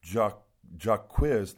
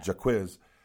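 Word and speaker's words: Jacquizz: So, Jaquizz, that's how you say it, yeah. Jaquizz.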